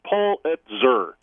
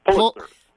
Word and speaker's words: Pulitzer: By the way, when we were on break, I went to one of those wonderful internet pronouncer things. And It's Pulitzer. – Pulitzer. Thank you. All right. This is something that I will now remember for the rest of my life. I've been doing it wrong.